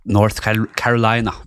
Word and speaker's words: North Carolina: Så vår mann eh, Frank Lucas han ble født i 1930 ute på landet i North North Carolina.